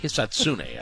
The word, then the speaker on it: Hisatsune: Oh, man, it took you a little while to get there, but Hisatsune, I think, is how you say it.